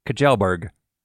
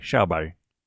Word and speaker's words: Kjellberg: It looks to me like it should be said Kjellberg. But I know that's not correct. So we listened to a Swedish person online say his name, and it sounded like Schauberg. So that's how I'm going to say it from now on. Anyway, you've probably heard of him.